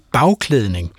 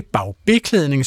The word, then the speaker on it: bagklædning: nemlig bagklædning. Altså ikke bagbeklædning, som andre kunne sige, men bagklædning.